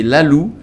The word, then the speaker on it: Lalug: Et c'est Lalou. Alors ça s'écrit Lalug, hein, je vous préviens. Mais c'est Lalou.